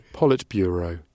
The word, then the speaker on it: Politburo: Politburo.